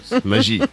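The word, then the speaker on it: Maggi: Bravo Elisabeth Maggie qui n'a pas inventé par contre le Maggie qui se prononce Magie.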